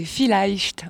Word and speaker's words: Vielleicht: Pourquoi ce titre Village? – Alors, je reprends la prononciation. C'est Vielleicht, on prononce. C'est de l'allemand.